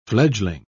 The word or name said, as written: fledgling